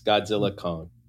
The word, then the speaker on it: Godzilla x Kong: Silent X, by the way. Silent X. Godzilla Kong. – Oh, Godzilla Kong? – The X. – You don't pronounce the X. – Yes. – But there's an X in the title. – But you don't pronounce it.